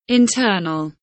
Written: internal

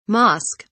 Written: mosque

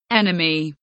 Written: enemy

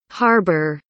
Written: harbor

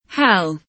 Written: hell

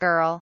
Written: girl

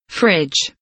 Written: fridge

